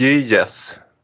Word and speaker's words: Gyges: ja, jag började fundera på, vet någon av er hur man uttalar Gigasringen? – Nej, men det låter rätt snuskigt. – Gyges.